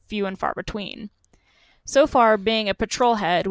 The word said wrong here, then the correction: petrol head: which if anyone doesn't know what that means, it means you're really into like cars and automotives. So far, petrol head. – Did I say it wrong? – Yeah. – Petrol head.